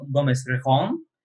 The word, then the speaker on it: Gomez Rejon: Także USA 2019, film w reżyserii, przepraszam, pozwolę sobie przeczytać, Alfonso Gomeza Regona, przepraszam, kurczę, chyba zarżnąłem wymowę jego nazwiska, nie? – Alfonso Gomez Rejon.